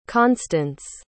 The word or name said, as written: constance